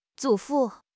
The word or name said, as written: 祖父